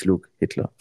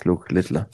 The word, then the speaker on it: Luke Littler: Jetzt wüsste, er heißt nicht Luke Hitler. er ist Luke Littler.